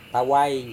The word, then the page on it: tawai: Serewen